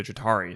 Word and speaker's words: Chitauri: Like, if the Chitauri, is that how you pronounce the name? – Yeah.